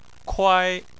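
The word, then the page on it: kuai: tone 1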